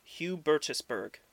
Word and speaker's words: Hubertusburg: Hubertusburg.